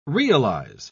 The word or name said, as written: realize